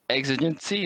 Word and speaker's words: exigency: What exactly is financial exigency? Is that the way you pronounce it? – That's exactly right.